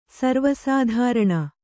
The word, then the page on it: ಸರ್ವೇಸಾಧಾರಣ: sarvē sādhāraṇa